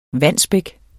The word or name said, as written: Wandsbek